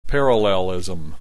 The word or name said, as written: parallelism